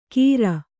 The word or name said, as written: ಕೀರ್